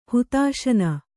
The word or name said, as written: ಹುತಾಶನ